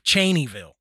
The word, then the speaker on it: Cheneyville: But near the little town of Cheneyville, I'm pretty sure I'm pronouncing that right. – Yep.